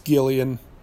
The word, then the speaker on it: Gillian: Is it Gillian Jacobs or Jillian Jacobs? I'm told it's Gillian by my roommate. I don't know if I've ever heard anybody else call her Gillian, but, you know, it's spelled with a G.